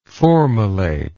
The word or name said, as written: formylate